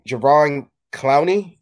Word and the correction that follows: Jadeveon Clowney: Jadeveon Clowney.